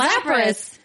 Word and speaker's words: Lapras: Oh, it's Lapras, isn't it? – Lapras. – Yes, it is. Lapras.